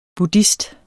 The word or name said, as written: buddhist